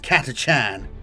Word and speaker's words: Catachan: and yes, it's pronounced Catachan, and Valhallan regiments in detail.